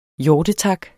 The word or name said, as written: hjortetak